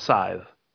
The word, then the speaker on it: scythe: It's a scythe. – Okay. – Scythe. – Some people pronounce the C, but I don't think you're supposed to.